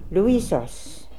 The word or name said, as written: Looisos